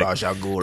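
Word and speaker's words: Ra's al Ghul: He was Rajah Ghul's or however you fucking pronounce it. – Rajah Ghul. – Because everyone pronounces it 15 fucking different ways.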